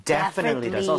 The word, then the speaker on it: definitely: 자, 그런데 강세를 여러분들 이쪽에 줘보는 거예요. 이렇게 하게 되면 definitely 이렇게 앞쪽에 강세가 가면서 뒤에 틀리라고 할 여유가 안 생기게 돼요. – 네. – 그렇게 돼서 definitely 이런 이상한 발음이 아니라 definitely 이렇게 발음이 될 수밖에 없습니다.